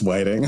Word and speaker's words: Whiting: Is it Whiting or is it Whiting? I meant to ask you about that. How do they pronounce it? – Yeah, how do they pronounce it? I've never known. – It's Whiting.